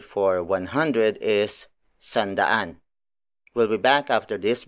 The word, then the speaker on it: sandaan: And then finally, for 100, it's sandaan.